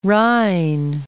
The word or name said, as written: Rhein